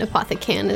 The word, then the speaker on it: Apothecan: And Apothecan is spelled C-A-N with all in caps. Like apothecary, but he can do anything.